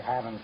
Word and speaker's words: having: Don't say having some fun. Say having. Pronounce the G.